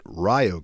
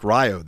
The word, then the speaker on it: Rio: Guess how they pronounce it? Rio Grande University. It's pronounced Rio there.